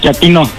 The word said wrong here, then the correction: chatino: No, chatino. – Es como chato, ¿verdad? Chatino. – Sí, asunero, como chato, chatino.